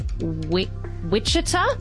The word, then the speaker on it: Wichita: She completed her internal medicine residency at KU Wichita.